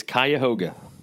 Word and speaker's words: Cuyahoga: I think I figured it out. It's Cuyahoga.